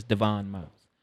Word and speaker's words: Devon: Oh, he's Devon. Devon, yes. Okay. Devon Miles. – Okay. – But it say Devin Miles but it's Devon Miles and um Sean.